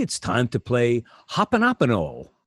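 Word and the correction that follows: Ho'oponopono (Hoʻoponopono): Did I say it right? – That was the best. Ho'oponopono. – Ho'oponopono.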